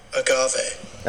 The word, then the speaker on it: agave: How do you pronounce agave? Alright, ready everyone? This is how we do it. – Agave. – Ah, I was right! – Agave. – Agave, yes.